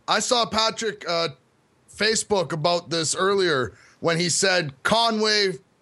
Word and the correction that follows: Kanye: for president 2020. – You're mispronouncing it. – Oh, so Kanye. Yes. Kanye. Oh.